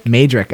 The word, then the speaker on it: Mejdrich: Mejdrich, okay.